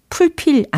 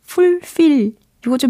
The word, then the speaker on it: fulfill: 발음은 fulfill, fulfill 이렇게 하시면 되는 거죠. fulfill, fulfill, 풀필 아니고 fulfill 이거 좀 비슷해지고 있는 단계고 fulfill 완벽해지는 단계가 되겠죠.